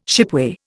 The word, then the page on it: shipway: Indian